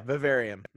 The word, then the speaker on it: Vivarium: Again, Vivarium, like, did I pronounce it right? Vivarium? – Yeah, Vivarium. Mm-hmm.